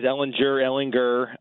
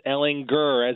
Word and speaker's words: Ehlinger: Ehlinger, right? – It is Ehlinger. I've heard it both ways, Ehlinger, but it is Ehlinger, as in Austin Powers, baby. Gurr, baby.